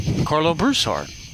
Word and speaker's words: Carlo Broussard: No, it's Broussard. Not Broussard. – Broussard. – The emphasis is on the ooh.